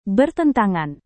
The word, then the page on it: bertentangan: indonesian